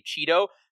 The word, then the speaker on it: Chido: but if you just want to say Chido, so it's like Cheeto, like Flaming Hot Cheetos, listeners, but with a D instead of two Ts in that situation.